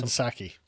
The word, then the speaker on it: Psaki: So someone from Fox News asked the question to, how do you say her name, Paxi? – Jen Psaki.